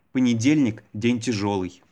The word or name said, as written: понедельник день тяжелый